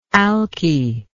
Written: alky

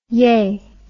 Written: yeh

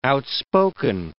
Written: outspoken

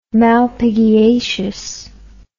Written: malpighiaceous

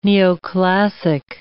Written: neoclassic

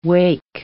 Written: wake

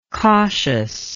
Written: cautiousness

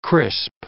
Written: crisp

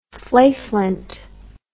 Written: flayflint

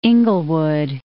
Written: Inglewood

